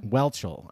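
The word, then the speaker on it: Whelchel: Welchel.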